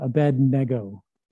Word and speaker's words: Abednego: Abednego, and I put the hyphen there because that's how you pronounce it, Abednego.